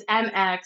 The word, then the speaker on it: Mx: And it's MX and it's pronounced like mix.